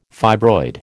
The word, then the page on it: fibroid: American English